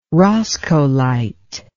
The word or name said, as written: roscoelite